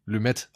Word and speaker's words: Lumet: Und ich sage bewusst Lumet, denn ich habe mir sagen lassen, sein Name wird Lumet ausgesprochen und nicht Lumet, wie ich mindestens 30 Jahre lang dachte.